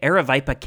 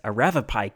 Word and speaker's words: Aravaipa: On September 14th, this small force marched into Aravaipa Canyon, which I believe I've been mistakenly pronouncing as Aravapai Canyon in previous episodes, so I apologize.